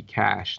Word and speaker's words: cache: I really like the word uh, C A C H E, cash, not C A S H, cash.